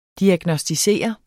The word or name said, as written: diagnosticere